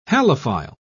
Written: halophile

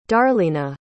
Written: darleena

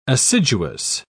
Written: assiduous

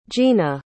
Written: jeena

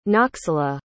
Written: noxolo

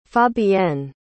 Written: fabienne

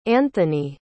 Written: anthoney

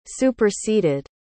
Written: superseded

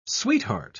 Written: sweetheart

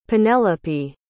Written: penelope

